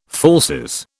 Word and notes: falsies: British English

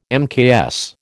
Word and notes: mks: American English

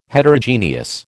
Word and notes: Heterogeneous: American English

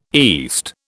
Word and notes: east: British English

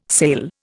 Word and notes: sale: Indian

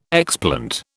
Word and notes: explant: British English

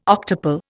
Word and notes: octuple: Indian

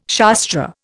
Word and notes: Shastra: Indian

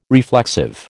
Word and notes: reflexive: American English

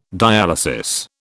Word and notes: dialysis: British English

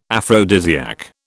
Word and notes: aphrodisiac: British English

